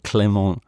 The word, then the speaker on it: Clément: No clemency for Kovac, but Clément, which is of course how you pronounce it, not what I did before, for Monaco.